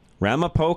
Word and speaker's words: Ramapo: Ramapo College of New Jersey.